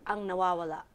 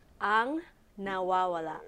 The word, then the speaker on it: Ang Nawawala: Ang Nawawala. – What? – Ang Nawawala.